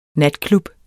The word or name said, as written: natklub